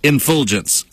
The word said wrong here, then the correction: effulgence: I'm sorry, effulgence.